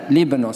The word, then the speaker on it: Líbano: Al norte de la frontera de Israel, en el presente Líbano, o Líbano se dice, Líbano se dice en español.